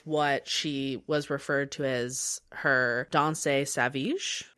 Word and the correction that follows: sauvage: I believe, is how I have it written down phonetically. – Sauvage.